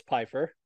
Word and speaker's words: Pfeiffer: By the way, I thought you were like pronouncing the silent P in Pfeiffer. It's Pfeiffer.